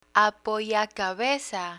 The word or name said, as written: apoyacabeza